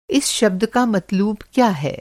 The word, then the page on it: मत्लूब: مطلوب